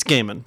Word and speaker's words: Gaiman: You said Gaiman? – It's Gaiman. – Oh. – I misspoke. – I was like, you're in the comics industry. – I know. – Have I been saying it wrong? – No, it's Gaiman.